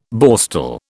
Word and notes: Borstal: British English

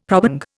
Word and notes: probang: Indian

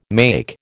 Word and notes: make: American English

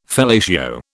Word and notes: fellatio: British English

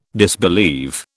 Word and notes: disbelieve: British English

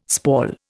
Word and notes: spall: Indian

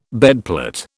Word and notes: bedplate: British English

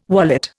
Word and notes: wallet: Indian

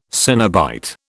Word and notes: cenobite: British English

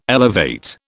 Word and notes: elevate: British English

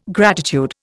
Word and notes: gratitude: Indian

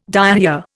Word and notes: diarrhoea: Indian

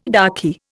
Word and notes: raki: Indian

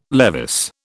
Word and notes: Levis: British English

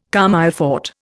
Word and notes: comme il faut: Indian

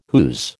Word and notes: whose: American English

